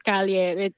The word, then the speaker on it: Kalye: Anna, you probably like noticed the word Calye. It's just we spell it K-A-L-Y-E, but it's basically from Spanish Calye.